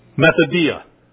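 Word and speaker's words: methodia (μεθοδεία): If you're looking out, spell it M-E-T-H, like meth, some of you take. Methodia. M-E-T-H-O-D-E-I-A.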